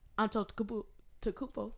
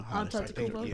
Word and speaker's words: Antetokounmpo: Then you got Giannis Antetokounmpo. Did I say it right? – Yeah. – I don't know. – Antetokounmpo. – Yeah, that was right.